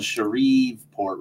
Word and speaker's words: Shreveport: Now it's spelled Shreveport, right? – That's right. – But it's just Shreveport.